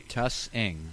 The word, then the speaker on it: Tussing: T-U-S-S-I-N-G. – Tussing. – Yes. It's just like if you were saying fuss. – It's not. Did they say Tussing? – They said Tussing on WPNS 10 TV.